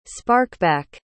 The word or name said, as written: sparkback